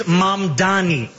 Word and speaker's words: Mamdani: The name is Mamdani, M-A-M-D-A-N-I. You should learn how to say it.